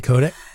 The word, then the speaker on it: Kotick: Five bipartisan members of the United States Congress have sent Activision Blizzard CEO Bobby Kotick. Am I pronouncing that correctly? – Yeah, Bobby Kotick.